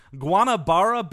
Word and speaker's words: Guanabara: I'm going to mispronounce this. Guanabara Bay? Is that what it's called? – Nope, I'm pretty sure that's exactly the right pronunciation.